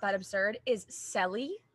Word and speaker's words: Cely: And it's spelled, her name is spelled C-E-L-Y, Selly.